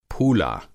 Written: फूला

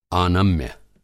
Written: आनम्य

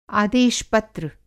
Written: आदेश-पत्र

